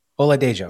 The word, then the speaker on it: Oladejo: Is it Oladejo? How do you say it? Oladejo.